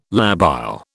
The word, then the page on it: labile: British English